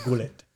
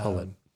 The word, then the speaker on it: Gullit: Gullit. – It's actually pronounced Hullet.